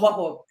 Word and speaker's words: Waho: Waho. Waho.